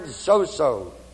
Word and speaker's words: sozo: And the word saved is the word sozo, S-O, long O, Z-O, long O. Meaning to be made whole, W-H-O-L-E.